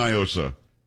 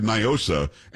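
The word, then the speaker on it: NIOSA: Do not, because it's definitely Nyosa. It is not a Spanish word. What does Nyosa mean in Spanish? Nothing. – So, I don't know. – Well, that's the whole argument. They try to pronounce it like it's Spanish. It's not. It's an acronym. And for those around the country, it's Night in Old San Antonio. And here we've always said Nyosa.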